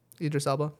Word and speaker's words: Idris Elba: Idris Elba, yeah.